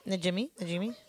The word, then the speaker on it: Najimy: Najimy.